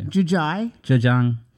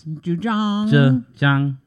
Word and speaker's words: Zhejiang: Zhejiang. – It's the middle province. – Zhejiang.